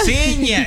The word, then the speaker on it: Bocinha: Bocinha, ¿sí, verdad? – Bocinha, sí, eso.